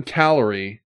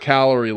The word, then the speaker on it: Callery: A-Z-O-R-E-S. Songs Correct. Of Hope for Haiti, the Azores song, Allison Callery, and it's not calorie like calories, it's C-A-L-L-E-R-Y.